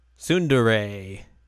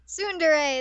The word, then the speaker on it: tsundere: Tsundere. – Tsundere.